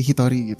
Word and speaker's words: Hito: Terus jadi sebenarnya nama channelnya sendiri itu aku nge-intended ini tuh dibacanya seperti Hitori gitu. Kalau di Jepang kan itu artinya sendirian ya. Sendirian gitu. Cuman kalau untuk nama akunya sendiri Hito gitu. Jadi bisa langsung panggil aja Hito.